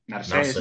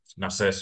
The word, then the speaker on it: Narsés: Narsés. – Narses. Narses. Sí. con acento en la E.